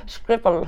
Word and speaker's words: Schiphol: Schiphol.